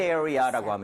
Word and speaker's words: area: 대신에 발음할 때 전에 말씀드렸죠. 자기도 모르게 area. 이렇게 해서 R 사운드 내는 경우 많다. – 맞아요. – Right. 그래서 area 하고 완전 끊어내세요. R 하지 마세요.